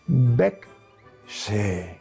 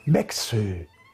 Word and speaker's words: berceuse: Bec-se. Bec-se. Se escribe Berceuse. Entonces, chicos, pregunto, ¿con qué nombre se conocen a las canciones de cuna en Francia?